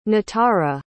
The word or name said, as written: natara